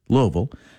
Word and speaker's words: Louisville: Bourbon and Beyond 2026, the world's largest bourbon, food, and music festival, takes over Louisville, and it is pronounced Louisville if you live there,